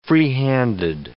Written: freehanded

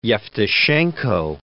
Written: Yevtushenko

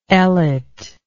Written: Ellet

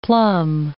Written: plum

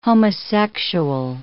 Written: homosexual

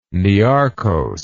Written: niarchos

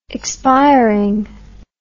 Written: expiring

